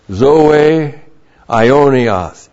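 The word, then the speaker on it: zoe aionios: No believer in cosmic death, no murderer, no believer who hates his fellow believer has zoe ionias. That's Z long O long E A I long O N I O S. Zoe Ionios, eternal life.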